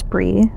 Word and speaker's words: Brie: Um, I have two different nicknames, one of which is uh, Cheese, because my nickname or my name is Brie, spelled like the cheese.